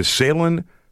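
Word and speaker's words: Sailun: Yeah, you know, and while we're talking about this, you can go to Salen.ca. – Mm-hmm. Salen Tires.ca. – Salen Tires.ca. And it's spelled S-A-I-L-U-N. T-I-R-E-S.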